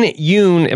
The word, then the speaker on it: Yeun: Is Steven Yeun, am I pronouncing that right? – Yeah, I believe so.